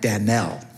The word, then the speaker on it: Danel: And his name is, you would pronounce it like, instead of Daniel, it would be more like Danel.